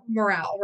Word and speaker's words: morale: and we have spiritual morale, right? I think this is morale, right? Spiritual morale. I said it wrong earlier.